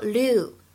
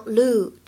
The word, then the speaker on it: lieu: 在这句话的朗读过程当中呢，我们注意一下 l i e u 这个单词呢，它有两种发音的可能，要么呢就是像刚才视频原声当中读作 lu，要么呢就可以读成 lu，长元音的 u 啊也是可以的。